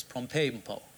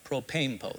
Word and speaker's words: propempo: Propempo, that's what it is, propempo. There you go. You like that? Propempo. That's how the word is pronounced.